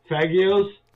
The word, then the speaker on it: Faggio: Faggios. – Faggios. – Faggios. – Yeah, it's pronounced Faggio, Rhodey.